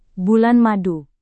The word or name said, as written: bulan madu